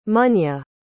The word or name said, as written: munya